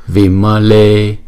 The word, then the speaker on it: Vimale: Vimale, chữ E đọc hai nhịp.